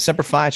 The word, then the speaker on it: Semper Fi: Semper Fi, how do you say it? No, that's right. Semper Fi, short for Semper Fidelis, means always faithful.